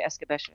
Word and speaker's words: escabeche: Escabeche? – Well, escabeche. There's a few different ways to pronounce it, but I would say escabeche.